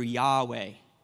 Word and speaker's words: Yahweh: And we pronounce it in the Hebrew, Yahweh. I am the everlasting one.